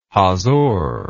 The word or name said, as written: hazor